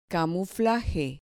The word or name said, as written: camuflaje